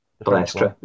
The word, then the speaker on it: Balestre: You say his name. – Balestre. Yeah. – That's him, Balestre.